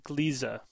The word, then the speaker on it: Gliese: But I was informed by a listener that it's actually a German word, and it's supposed to be pronounced Gliese, as two syllables, not one.